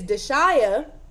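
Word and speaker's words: Dashiya: See, and that's because my name is Deshaya.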